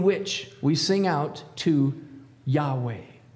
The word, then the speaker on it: Yahweh: Then we add some vowels to help make it able to be pronounced. And in between the Y and the H, you can write an A. And after the W and the final H, you can write an E. And now we have a word that can kind of be pronounced, Yahweh.